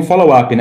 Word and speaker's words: Follow-up: Bom, eu, eu te apresentei aqui como agência FUB, mas vocês pronunciam follow-up, né?